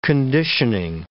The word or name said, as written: conditioning